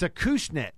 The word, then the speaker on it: Acushnet: A cushnet. A cushnet. But it's a Kushna? It's a it's a Kushnet. You That's, pronounce the T, yeah. – That, that, that is the correct pronunciation.